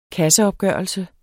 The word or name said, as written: programtype